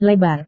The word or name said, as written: lebar